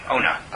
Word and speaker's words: Ona: Be careful in the pronunciation. Ona. – Ona. – Right. Oh, no, is abuse.